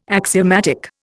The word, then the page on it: axiomatic: Indian